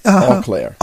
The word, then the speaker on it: Eau Claire: Oh, Benton Harbor, Eau Claire.